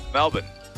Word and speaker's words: Melbourne: How did you say Melbourne? – Melbourne. The correct pronunciation. That's the correct pronunciation if you ask an Australian.